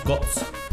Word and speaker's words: cots: Es una, la palabra cots, cots. Así se pronuncia, cots, cots.